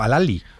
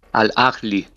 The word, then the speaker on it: Al Ahly: Por cierto, ahora que te oigo pronunciarlo, para que lo pronunciemos bien, ¿cómo se pronuncia? Porque nosotros estábamos diciendo Al Ahly y te he oído decir como Al Ahly. – Al Ahly. – Sí. Al Ahly, sí, vale, sí. Vale. – Lo vamos ya, te lo he dicho bien, ¿eh? – Sí, lo has dicho bien.